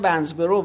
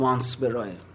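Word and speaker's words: Wansbrough: بیشتر میگن ونزبرو، ولی من تو فورو رفتم نگاه کردم تلفظ صحیحش وانسبراه.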